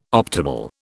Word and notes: optimal: British English

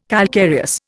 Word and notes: calcareous: Indian

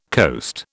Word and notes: coast: British English

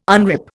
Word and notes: unrip: Indian

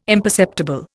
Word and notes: imperceptible: Indian

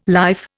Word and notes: life: Indian

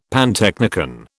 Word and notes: pantechnicon: British English

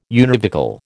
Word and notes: univocal: American English

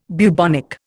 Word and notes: bubonic: Indian

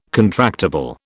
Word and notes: contractible: British English

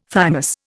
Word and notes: thymus: Indian

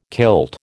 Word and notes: kilt: American English

